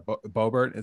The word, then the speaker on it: Boebert: I think politicians like Marjorie Taylor Greene and Laura Boebert, is that how you pronounce her last name? Yeah.